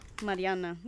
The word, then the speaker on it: Mariana: It's going to be Callie and Mariella. Ooh, am I saying that right? – Mariana.